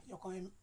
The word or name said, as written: Yokoim